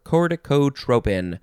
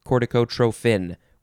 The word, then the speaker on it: corticotropin: you can spell it, oh, instead of tropin, you can pronounce it and spell it trophin with a PH. Although, is it, you know, it's not a pronunciation. No, it is. Oh my God, I'm so sorry. Corticotropin or corticotrophin with a F.